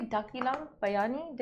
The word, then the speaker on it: Dakilang Bayani: Did I say Dakilang? Bayani? Did I say it wrong all this time? What were you saying before? – Daki. – Did I say Bakilang Bayani? – Yeah, yeah. Okay, Dakilang Bayani.